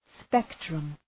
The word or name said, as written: spectrum